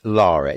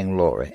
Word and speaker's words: Lara: I should pronounce it properly. Laura. It isn't Laura. We used to say Laura. It's Laura. L-A-R-A.